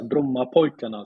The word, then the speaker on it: Brommapojkarna: Yeah, Bromma Pojkarna.